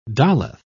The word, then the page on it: daleth: British English